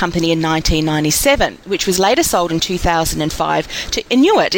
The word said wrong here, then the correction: Intuit: Is that how you pronounce it, Nelly? Inuit? – It's Intuit. – Correct. Intuit.